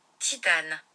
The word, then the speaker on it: Titane: How do you pronounce titanium in French? – In French, titanium is titane.